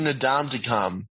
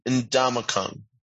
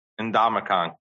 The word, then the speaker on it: Ndamukong: I'm saying Nadam to come. – Yeah, you, like I said, you sound like you're trying to say Donkey Kong. – Well, N-D-N-D-A-M-U-K-O-N-G. Ndamukong. – Ndamukong.